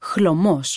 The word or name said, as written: χλωμός